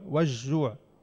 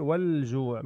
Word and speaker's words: والجوع: والجوع لما نقول والجوع، والجوع ما لفظنا اللام، الجيم من الأحرف اللي بتلفظ معها اللام، شو بتصير؟ والجوع، من الخوف والجوع.